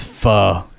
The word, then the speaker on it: pho: It's pronounced pho. It's spelled pho.